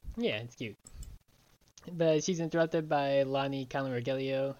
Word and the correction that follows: Rogelio: I always forget how to pronounce Rogelio. – Rogelio.